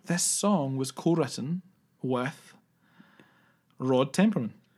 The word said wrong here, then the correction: Temperton: Rod Temperton, sorry. – Temperman. – Temperton. I don't think we're ever going to pronounce this guy's name right.